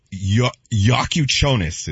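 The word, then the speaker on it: Jakučionis: Kellel Ware, and then the guard, Yakuchonis is how you say his last name.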